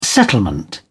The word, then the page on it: settlement: British English